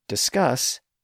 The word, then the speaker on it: discuss: By the way, notice the difference between discuss and discus, as in a small round object that you throw.